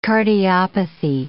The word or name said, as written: cardiopathy